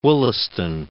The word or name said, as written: Wollaston